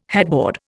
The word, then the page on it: headboard: Indian